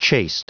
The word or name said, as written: chaste